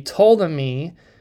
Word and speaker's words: Ptolemy: Again, for familiarity, but they were the Ptolemy uh, kingdom. That's spelled P, P silent, T O L E M Y. All right, that's how you spell that.